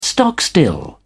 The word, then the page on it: stock still: British English